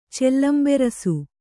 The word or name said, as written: ಚೆಲ್ಲಂಬೆರಸು